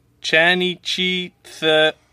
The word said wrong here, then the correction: Chanichthyidae: Chanichthyidae. – Chanichthyidae? – Chanichthyidae. – Chanichthyidae. Okay, how you say it is Chanichthyidae.